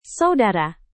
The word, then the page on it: saudara: indonesian